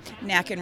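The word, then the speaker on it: Nakken: And it really is Nacken, and our West Coast correspondent gave me the handy-dandy mnemonic of Nacken rhymes with Kraken.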